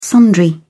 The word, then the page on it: sundry: British English